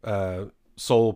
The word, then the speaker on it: Sowell: So this one is coming from Thomas Sowell, Sowell pronounced or spelled out S-O-W-E-L-L.